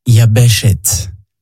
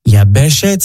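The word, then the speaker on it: Yabeshet (יבשת): Il se dit en hébreu « Yabeshet ». « Yabeshet » s'écrit de la manière suivante « Yod, Bet, Shin, Tav ».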